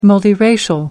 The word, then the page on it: multiracial: American English